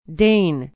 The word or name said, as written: Dane